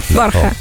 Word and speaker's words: Borja: No, no, perché tu lo dici benissimo in realtà, lo dici alla spagnola, no? Con dici? – La ota che non si pronuncia, no? Giustamente in spagnolo, sì, la cota. – Borja.